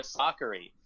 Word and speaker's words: Sakkari: So now we know it's Maria Sakkari, and we know it's Vondrousova instead of Vondrousova.